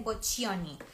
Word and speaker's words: Boccioni: Boccioli, boccioni, boccioni. Sí, porque es doble C, boccioni.